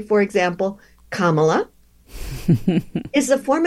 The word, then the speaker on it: Kamala: I know someone whose name is Kamala. That's how she says her name. But Kamala Harris pronounces it Kamala.